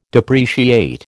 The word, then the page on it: depreciate: American English